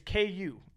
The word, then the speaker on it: Ku: I told you guys this at the beginning of the podcast, but if you guys don't know how that's spelled, it's K-U, and you guys hear us pronounce Ku. Like, cool.